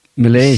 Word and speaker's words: Milley: Milley.